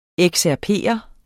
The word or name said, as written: excerpere